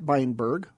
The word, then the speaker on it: Beienburg: We have Education Policy Director of the Goldwater Institute, Matt Beyenberg.